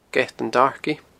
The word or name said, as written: geatandarkie